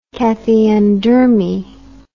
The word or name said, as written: cathiodermie